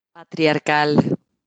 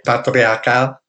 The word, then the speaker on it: patriarcal: Um, how do you say patriarchal in uh, Spanish? – Patriarcal. – Oh, okay, patriarcal.